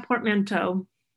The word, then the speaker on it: portmanteau: I'm always so amazed at your thinking, and this is really, so now I know what a portmanteau, how do you say it? Portmanteau? – Yeah, portmanteau.